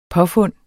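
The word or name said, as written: påfund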